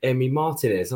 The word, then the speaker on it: Emi Martinez: I was so just about to praise you because you said Emi Martinez. I was thinking he's getting better. He's pronouncing it right.